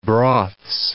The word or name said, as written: broths